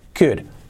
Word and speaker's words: could: Escrito cold, la pronunciación es could.